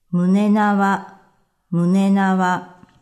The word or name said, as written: Munenawa